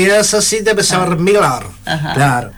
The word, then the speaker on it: Müller: Pero bueno, la pronunciación correcta más o menos es Müller. – Müller, claro. Müller. Tienes que poner la boca de U, pero decir boca Müller. De U y pronunciar la I.